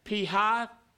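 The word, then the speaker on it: Pi-hahiroth: Now the Lord spoke to Moses, saying, "Tell the sons of Israel to turn back and camp before Pihahiroth." All right? That's how you say it. I looked it up.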